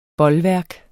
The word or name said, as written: bolværk